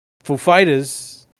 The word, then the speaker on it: Foo Fighters: And I mentioned this to you the other day, where Christopher Walken, there's a story where he asked them, is the emphasis on the Foo or the Fighters? And they said Fighters. So he says, ladies and gentlemen, Foo Fighters.